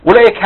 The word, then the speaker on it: أولئك: طيب الواو هنا بتاعت أولئك هنلاقيها مش منطوقة. أولا في ناس بتنطقها يقول لك إيه أولا. – تمام. – نقول لا دي عليها صفر مستدير يعني ده حرف إيه غير منطوق وصلا ولا إيه وقفا.